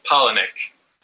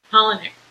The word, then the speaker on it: Palahniuk: I'm saying his last name wrong. Chuck Palahniuk? – Palahniuk. – Palahniuk. Okay, great.